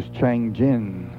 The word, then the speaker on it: Changjin: As a matter of interest, for what it's worth, Chosin, Chosin, is the Japanese name for that reservoir. For our Korean listeners, it is Changjin. Changjin Reservoir is the Korean pronunciation.